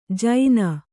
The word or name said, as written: ಜೈನ